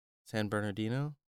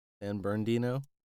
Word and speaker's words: San Bernardino: San Bernardino? San Bernardino, if you're being quick about it.